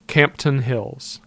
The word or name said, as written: Campton Hill